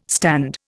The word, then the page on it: stand: Indian